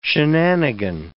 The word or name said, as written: shenanigan